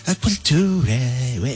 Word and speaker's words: Aquaculture: Qué buena pronunciación. – Aquaculture.